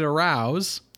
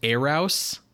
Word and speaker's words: Arous: The sci-fi film The Brain from Planet Arouse. – Excuse me. – It might be pronounced Arouse in the movie, but it is spelled A-R-O-U-S.